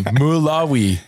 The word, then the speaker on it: Malawi: Mulawi. He's helping some kids with some eye and vision care, but he's also making Oiler fans out of them. So it's wonderful to see he's down in Mulawi making kids' lives better and also making Oiler fans.